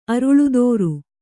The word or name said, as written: ಅರಲ್ತರ್